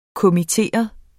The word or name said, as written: kommitteret